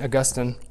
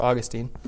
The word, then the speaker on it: Augustine: We start with God and then understand everything else from there, which is summarized well in a quote from St. Augustine, or Augustine, pronounced both ways.